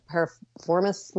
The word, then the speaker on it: piriformis: I had an issue with a piriformis muscle, I think is what how it's pronounced.